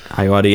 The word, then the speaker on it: Ayoade: I also enjoy Mighty Boosh, and the show you were thinking of but couldn't remember the name of, Richard Ayoade, is how you pronounce his name? – Ayoade, yeah.